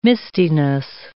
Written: mistiness

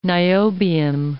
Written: niobium

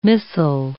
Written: missal

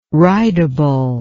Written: ridable

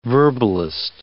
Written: verbalist

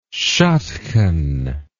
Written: shadkhan

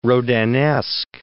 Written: Rodinesque

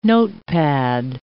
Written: notepad

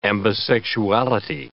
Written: ambisexuality

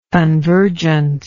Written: convergence

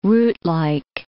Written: rootlike

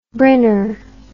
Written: Brynner

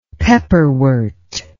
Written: pepperwort

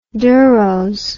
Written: deros

